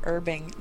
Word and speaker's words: herbing: MacGyver says you mean herbing, not herbing. No, it's herbing. Herbalism. The H is silent.